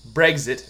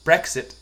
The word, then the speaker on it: Brexit: And it's not E-G-Z-I-T, it's E-X-I-T, so I say exit instead of exit, and you were saying Brexit instead of, I call it Brexit, because it's exit, because it's spelled with an X. – Really? You think I was saying Brexit with a G? – You definitely were.